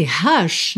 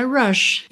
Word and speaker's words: rush: Só que esse rush, na verdade, é rush.